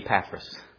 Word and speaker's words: Epaphras: Well, in the South, we say Epaphras.